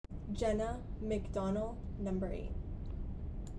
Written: Jenna McDonnell

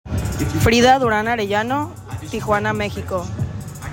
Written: Frida Duran Arellano

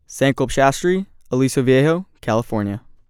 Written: Sankalp Shastri

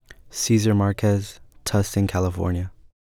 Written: Cesar Marquez

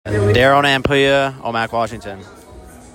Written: Darrell Nanpuya

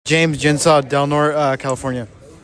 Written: James Gensaw Jr.

